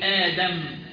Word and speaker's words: آدم: زي كلمة آدم أصلها أقدم، مفيش حاجة اسمها في اللغة العربية إنك تنطق همزة ساكنة بعد همزة متحركة، لازم تبدلها حرف بدل من جنس ما قبلها، تقول آدم.